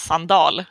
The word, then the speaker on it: Sandahl: kommer jag ihåg bara för att jag tycker att hans namn bör uttalas Sandal och inget annat.